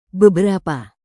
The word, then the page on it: beberapa: indonesian